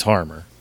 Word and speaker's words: Harmer: It's Harmer.